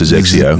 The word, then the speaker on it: XXIO: XXIO. So that's pronounced Zexio. – Did you make that up? The Zexio. Pronunciation, or that's how they say it? – No, that's how they say it.